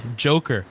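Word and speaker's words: Djoker: Best nickname in sports, by the way, Joker with a D. – The Joker with a D. – Yes, that's the best. Basketball does a really good job. – The D is silent.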